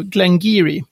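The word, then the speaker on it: Glen Garioch: Det stavas Glengarioch med CH på slutet. Det uttalas, håll i er nu, Glengeary.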